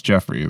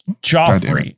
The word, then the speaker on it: Joffrey: I'm pretty sure it's Jeffrey, the middle one. – Wrong. Joffrey.